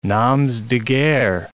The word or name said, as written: noms de guerre